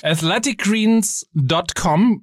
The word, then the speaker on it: Athletic Greens: Also wie du es jetzt ausgesprochen hast, das S nicht in der Mitte, nicht bei Athletic, sondern Athletic. – Völlig richtig. – Das S muss mit hin.